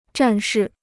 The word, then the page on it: 战士: zhàn shì